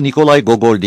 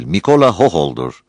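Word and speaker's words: Mikola Hohol'dur (Микола Гоголь): Onun adı Nikolay Gogol değil, Mikola Hohol'dur.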